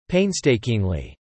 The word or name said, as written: painstakingly